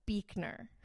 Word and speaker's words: Buechner: Frederick Buechner, B U E, says Buechner.